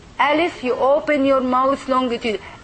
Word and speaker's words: Alif: You pronounce the Alif like the letter A in English. When you say Abel, okay, this is the A.